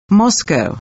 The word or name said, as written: MOSCOW